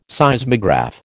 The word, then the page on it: Seismograph: American English